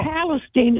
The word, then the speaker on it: Palestine: No, Palestine. It's spelled like Palestine, but Palestine. Okay.